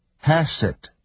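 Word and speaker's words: chesed (חסד): 所以我们要特别特别注意，这里有一个关键词可以帮助我们了解整个预言，希伯来文是 chesed， hesed。这个字第一个音节的发音是喉音，在英文里面并没有这个字。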